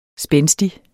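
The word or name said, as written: spændstige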